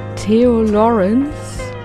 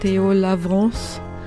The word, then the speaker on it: Theo Lawrence: Das war Theo Lawrence. Oder ich weiß jetzt nicht, wie die Franzosen das aussprechen würden, ehrlich gesagt. Theo Lavrance, peut-être.